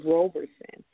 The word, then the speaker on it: Roberson: My name is Angela Roberson. A lot of people always say Roberson. I guess it's just an easy roll up.